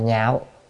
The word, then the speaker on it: nhạo: Chữ đảng này á có là chỉ, chữ này đọc là nhạo hoặc là lạc, nhưng mà đây là còn nhạo là ưa thích.